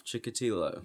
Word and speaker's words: Chikatilo: As I talk about Andre Romanoff Chikatilo. – Yay! – I actually said it right this time.